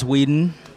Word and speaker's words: Weeden: Or I do have a social media account under Marcus Whedon. – Whedon, spell that for us. – W-E-E-D as in David, E-N.